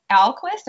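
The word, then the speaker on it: Ahlquist: So you heard this quote while interviewing Dale Alquist. Am I saying that right? – Yes.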